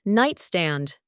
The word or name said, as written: nightstand